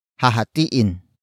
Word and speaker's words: hahatiin: Word for word it's hahatiin, ang, mana, nang, pantay-pantay.